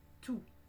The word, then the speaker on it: tout: Comme adjectif, on ne prononce pas le T à la fin. Tout.